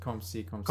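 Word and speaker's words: comme ci, comme ça: No, it's not come. You don't say that E. It's just come see, come ça. – Come ça, come see, come ça. – Come see, come ça.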